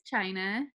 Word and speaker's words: Chynna: My name is Chynna, pronounced like the country. It's not spelled that way, but I own Little Mama Shirt Shop, and I have been running this business for just over 2 years.